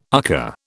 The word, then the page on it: UCCA: British English